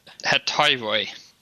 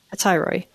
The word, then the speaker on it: Hetairoi: Heteriori? Is that how you say it? – Let me look at the pronunciation. Hetairoi. – Hetairoi. Yeah. Kind of a leader of her own house.